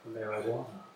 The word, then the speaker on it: marijuana: It's not a hard J, it's a soft J. – Oh, marijuana.